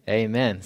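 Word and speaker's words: amen (ἀμήν): And this word in the Greek, it's actually amen. So you pronounce it the same way, and it just means firm or the affirmation of prayer.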